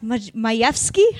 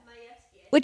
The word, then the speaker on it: Majewski: His last name is Majewski, but it looks to me like it just says Majewski. And I feel like but it's pronounced Majewski. How is it pronounced? – Yeah, Majewski. – It just sounds so aggressive. – Yeah, no, all those Eastern European...